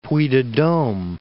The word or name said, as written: Dome, Puy de